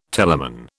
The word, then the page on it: telamon: British English